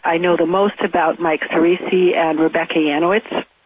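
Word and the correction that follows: Yanisch: I'm not sure if I'm pronouncing her name correctly. – It's Yanish. Yanish, thank you.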